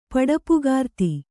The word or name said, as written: ಪಡೆಪುಗಾರ್ತಿ